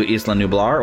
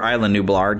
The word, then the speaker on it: Isla Nublar: She's the granddaughter of James Cromwell's Benjamin Lockwood, who is a millionaire who is basically funding all of this expedition back to Isla Nublar, or Isla Nublar, depending upon how you would pronounce that. We usually call it Isla Nublar for fans of the original movies, but I've been hearing, you know, Isla Nublar because, you know, people who are named Isla, they spell it the same way, but they'll pronounce it Isla, and if you think island, so I get it, you know.